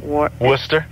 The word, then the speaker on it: Worcester: How do you say it? – Worcester. – Worcester, thank you.